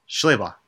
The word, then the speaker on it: Schlabach: I believe it was written by Mark Schlabach? – Schlabach. – Schlabach, that's it.